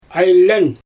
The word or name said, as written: ajlEn